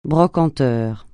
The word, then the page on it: brocanteur: francais